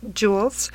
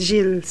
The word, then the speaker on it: Gilles: Um, Enchanted Jewelry was started in 1985 by my dad, Gil St. George, uh, sometimes pronounced Jules, um, but in French it's pronounced Gilles St. George, and I'm not a native French speaker.